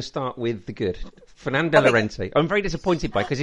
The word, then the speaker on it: Llorente: Yes, do you know how we pronounce it, though? You're going to be really excited by this. – Llorente? – Yes!